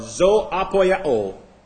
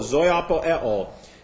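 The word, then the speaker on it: zoopoieo: Quickeneth is the present active participle of zoapoyao. Zoapoyao. Z-O, long O, O-P-O-I-E-O, zoapoeo.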